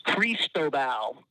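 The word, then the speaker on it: Cristóbal: The phonetic pronunciation is Cristobal, but I don't know who in a time-limited three-minute weather segment is going to go with all three. We certainly shortened it to say it as quickly as possible to just Cristobal. Mm. But yeah, phonetically, properly, it is Cristobal.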